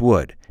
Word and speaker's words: would: And some people say touch wood. Now, in American English, we usually say knock on wood, but some people also use the expression touch wood. And remember, wood, which is spelled W-O-O-D, sounds just like W-O-U-L-D.